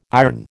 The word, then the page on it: iron: American English